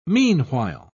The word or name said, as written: meanwhile